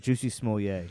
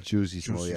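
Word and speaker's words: Jussie Smollett: For the listeners, it's a picture of Jesse Smollett next to 6'9", after they both got their ass beat. – Sorry, you pronounced his name wrong. It's Juicy Smollett. – Juicy Smollett, I'm sorry.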